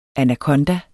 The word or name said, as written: anakonda